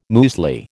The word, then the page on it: muesli: American English